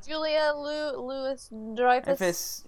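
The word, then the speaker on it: Julia Louis-Dreyfus: Julia Louis Dreyfus.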